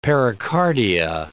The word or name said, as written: pericardia